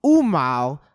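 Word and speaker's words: Åmål: When I said fucking Umau, Umau is spelled A-M-A-L. – I have no clue. – It's Swedish.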